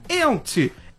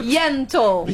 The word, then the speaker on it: Yentl: Ó, a querida Alessandra Maestrini, ela tá em peça, que é a Ent. Out in Concert. – Yentl.